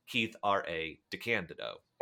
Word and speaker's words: Keith R.A. DeCandido: Please welcome back to the podcast, Keith R.A. DeCandido.